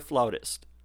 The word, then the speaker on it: flautist: Is the correct pronunciation for what you do with the orchestra flutist or flautist? – Both are correct. There's even a third, flautist.